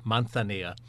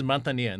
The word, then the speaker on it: Manzanilla: The two of my favorite sherries are Fino and Manzanilla, or as they pronounce it, Manzanilla. – Manzanilla. – Because you sure. – lisp for the Z's and C's. So this is Manzanilla, and this is actually from a winery that I got to visit.